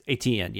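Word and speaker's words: Etienne: I don't know how to pronounce that. It's E-T-I-E-N-N-E. – ATN? – Yes, ATN, yes.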